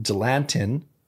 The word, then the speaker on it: Dilantin: Dr. Schlepp changed Anneliese's prescription from Dilantin.